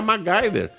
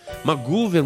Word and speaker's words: MacGyver: O, o MacGyver, qualquer pessoa que lê o jeito que se escreve MacGyver, nunca vai conseguir pronunciar MacGyver. Maguver, MacGyver, o que, que é aquele negócio lá?